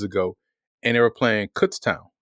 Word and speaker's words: Kutztown: And I'm saying it that way because that's how it's pronounced. But when you look at the doggone word, it's spelled K-U-T-Z town, right? So easily you're going to say Kutztown. So I spent all week saying, all right, it's Kutztown.